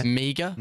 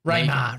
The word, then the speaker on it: Meagher: And that last name, what did you say? – Meager. M-E-A-G-H-E-R. Mate. – Raymar. I was like, he's saying it wrong.